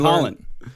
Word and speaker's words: Hollande: So because of the attacks in France, Hollande, the president over there, they don't pronounce the H. – No, it's Hollande.